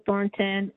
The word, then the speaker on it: Thornton: Yes, my name is Cheryl Thornton, and my uh, name is spelled Z H E R Y L Thornton, T H O R N T O N.